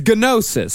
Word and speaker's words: gnosis: Is it gnosis or gnosis? – I think it's gnosis. – Gnosis. – How is gnocchi pronounced? Gnocchi? – Gnocchi. – It's like gnocchi. – The food? – It's gnosis. So let's say gnosis.